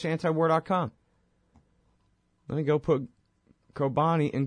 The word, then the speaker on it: Kobani: I said Cobain. Yeah, sorry. I don't know. All right. Sorry, I got a computer technical difficulty that I'm screwing up over here. Not your fault. Yeah, Kobani is how it's pronounced.